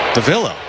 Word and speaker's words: Davila: We'd all been saying his name Davila this whole time, and we were talking to him because we could have sworn the audio came from himself that it was Davila. Here comes the 1-2. Swung on. Soft ground ball. That's just wide of first base, out of play. One ball, two strikes. And we asked him how to say it, and he said, Davila.